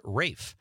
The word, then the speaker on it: Ralph: Jameson from Pennsylvania says, Ralph in this case is pronounced Rafe, but don't forget his brother Joseph Fiennes, who is also an excellent actor.